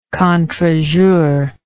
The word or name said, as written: contrejour